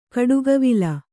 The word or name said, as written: ಗಿಡಗಾವಲು